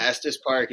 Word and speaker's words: Estes Park: Tony is injured in Estes Park. I don't know if that's correct pronunciation. – Estes Park is absolutely right. You said it.